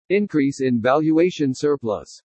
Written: INCREASE IN VALUATION SURPLUS

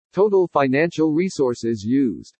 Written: TOTAL FINANCIAL RESOURCES USED

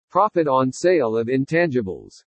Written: PROFIT ON SALE OF INTANGIBLES

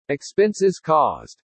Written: EXPENSES CAUSED

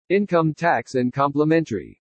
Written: INCOME TAX AND COMPLEMENTARY

